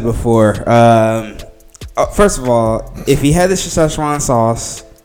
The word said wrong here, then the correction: Szechuan: Why are you putting too many syllables in? It's not Szechuan. – Or how do I say it? – Szechuan.